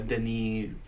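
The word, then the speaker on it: Denis Villeneuve: Dennis Villeneuve. – Yeah. – Yeah. – That's not how you say it at all, by the way. – No. – But it's like a Denis, something like that.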